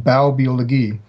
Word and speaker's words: Baubiologie: Baubiologie. So the German word Bau, B-A-U, is translated into English as building or home, and Biologie is their German pronunciation of our word biology. So